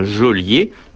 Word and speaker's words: geôlier: Il est heureusement assez rare de détenir des personnes chez soi, et le détenteur d'un objet volé, autrement appelé receleur, n'est pas un geôlier, qui s'écrit Géo, mais se prononce Jo.